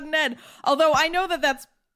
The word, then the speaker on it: Aedh: So two of them they were brothers and they are spelled A O D H and A E D H, which I pronounced Odd and Ed. – I think it's Odd and Ed. Just Odd and Ed.